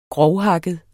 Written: grofthakket

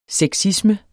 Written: sexisme

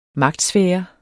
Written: magtsfære

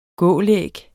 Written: gålæg

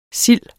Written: sild

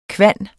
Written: kvan

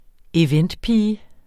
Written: eventpige

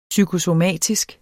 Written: psykosomatisk

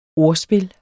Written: ordspil

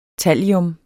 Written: tallium

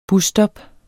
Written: busstop

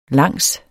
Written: langs

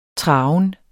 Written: traven

